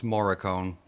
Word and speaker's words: Morricone: It's Morricone. You always say it wrong.